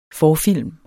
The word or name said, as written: forfilm